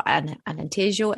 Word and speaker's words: Alentejo: It's the southern Alentejo, or Alentejo, actually. It's pronounced.